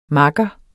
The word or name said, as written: makker